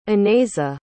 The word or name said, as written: anaisa